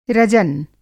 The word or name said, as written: रजन